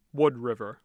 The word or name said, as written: Wood River